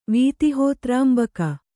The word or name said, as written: ವೀತಿಹೋತ್ರಾಂಬಕ